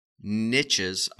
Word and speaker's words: niche: He's at some meeting out of town, and there are some small niches. I don't know if I'm saying that right. Niches? – It's a niche.